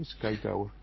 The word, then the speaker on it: Sky Tower: ¿Así está bien pronunciado? – Sí, Sky Tower.